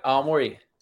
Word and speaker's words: Aomori: and I won't pronounce it properly, Aomori. – Yep, Aomori. – Aomori in Japan.